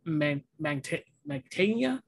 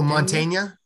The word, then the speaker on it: Mantegna: Joan Mantegna. – Oh, Mantegna.